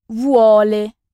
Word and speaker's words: vuole: Vous voulez, quand on vous voit quelqu'un, se dit en italien vuole. V-U-O-L-E. Vuole. Je vous rappelle que le U italien se prononce toujours U et que le son U n'existe pas.